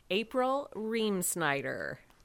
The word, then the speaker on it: April Reamsnyder: April Reamsnyder.